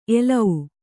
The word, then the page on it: ಎಲೌ: elau